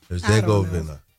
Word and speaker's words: Herzegovina: Today at 3, Switzerland, Bosnia, and Herzegovina. Did I say that right? – Herzegovina. You could just go with Bosnia.